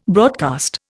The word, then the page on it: broadcast: Indian